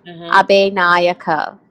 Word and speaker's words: Abinayake: And your surname, how do you pronounce your surname? It's not Abinayake. – So basically, I have two. So it's Jayasuraya Abinayake. – Abinayake. Okay. – Yes. – I pronounced it like I would like a West African name.